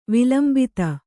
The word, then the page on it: ವಿಲಂಬಿತ: vilambita